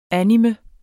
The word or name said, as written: anime